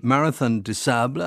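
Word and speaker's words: Marathon des Sables: Now, in the past, I'm told you've participated in the Marathon du Sable.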